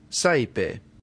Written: saepe

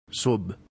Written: sub

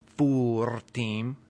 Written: furtim

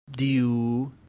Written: diu

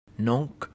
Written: nunc

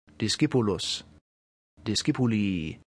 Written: discipulus

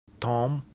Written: tum

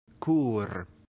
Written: cur

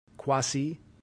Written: quasi

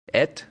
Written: et

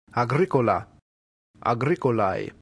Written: agricola

